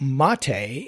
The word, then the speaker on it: Mate: Uh, so I would recommend for a brand new user Ubuntu Mate, and Mate is spelled the South American way, M A T E.